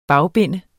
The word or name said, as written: bagbinde